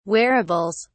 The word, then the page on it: wearables: British English